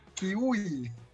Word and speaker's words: Qiui: Kiwi se escribe Q-I-U-I.